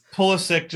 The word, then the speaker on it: Pulisic: Pulisic just has that hard C.